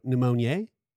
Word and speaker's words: pneumoniae: It's spelt like pneumonia, but you have an E at the end after the A. So pneumoniae, chlamydia pneumoniae. It's fancy.